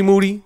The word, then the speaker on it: Moody: I'm in here with G Moody. Last name rhymes with duty.